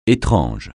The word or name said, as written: étrange